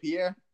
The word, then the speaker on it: Pere: Did I pronounce the last name right, Pere? – It's Pere, but no one gets it right, so it doesn't bother me at all. Most people say Pere.